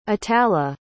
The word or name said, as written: itala